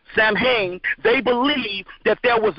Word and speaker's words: Samhain: It was a festival pronounced Samhain, and that's Samhain, Samhain.